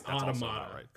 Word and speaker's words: Automata: And then I say it the other way, and I'm also Automata, because I think they say it in the game at one point. And Paul said it that way, and I remember thinking, oh, Paul was right, I was wrong.